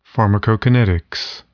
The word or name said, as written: pharmacokinetics